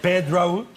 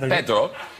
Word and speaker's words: Pedro: So anyway, if you want to pronounce it like if you were Spanish, excuse me that I'm so long, then it is Pedro. Yes, you said that. – Pedro. – Pedro, yes.